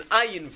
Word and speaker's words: ayin: Ayin is a guttural letter. It's not a silent letter.